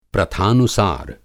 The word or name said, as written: प्रथानुसार